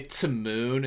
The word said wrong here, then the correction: Timon: instead of to moan.